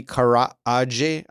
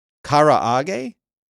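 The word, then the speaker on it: karaage: Okay, Eric is saying it is pronounced Karage. You're just trolling me. Karage?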